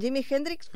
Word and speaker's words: Jimi Hendrix: Com es diu, a veure, en Jimi Hendrix, com es pronuncia? – Ho has fet molt bé.